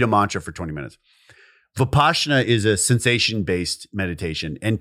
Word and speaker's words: Vipassana: Vipassana.